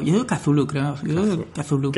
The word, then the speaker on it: Cthulhu: Entonces me he puesto ahí con Howard Philip, que ya pues en el instituto, sobre todo, jugando a la llamada de Cthulhu, Chulu, Cthulhu, cada uno como lo pronuncia. – Cthulhu digo yo. Cthulhu, yo digo Cthulhu.